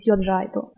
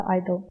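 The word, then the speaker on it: (G)I-DLE: Корейский вариант будет Idol или полный его произносит Yeo-G-Idol. Английский вариант как раз это G-Idol. Нет ничего страшного, если будут произносить люди тот или иной вариант, так как они все являются правильными. Однако сами девочки прочитают вариант без приставки, просто Idol.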